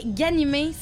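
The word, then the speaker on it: Ghanimé: Je parle ma page Facebook parce que mon nom de famille, ça s'écrit Ganimé. C'est animé avec GH en avant.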